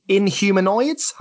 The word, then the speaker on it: Inhumanoids: If nothing else, I know personally I'm going to be checking out Inhumanoids. Have I said that right? – Yes. Yes. Yeah. – So I'm going to be checking out Inhumanoids as recommended by Frankie B.